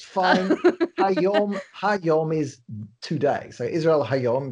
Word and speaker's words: Israel Hayom: No, in fact, I was reading Israel Hayom today. I'm probably saying that wrong. – Israel Hayom.